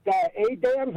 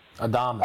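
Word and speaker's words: Adames: Yelich and, I don't know, is it Adams or Adamas? Really? – Adamas.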